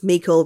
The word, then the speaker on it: Meikle: Juicy as Ross Meikle rhymes with treacle said on Instagram, I predict excellent banter.